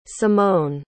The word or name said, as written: simone